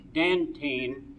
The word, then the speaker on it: Dantine: And I want to compliment Dr. Wood right here for the fine production of students represented by Jim Dantine, rhymes with Pepsodent.